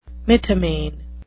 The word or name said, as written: mythomane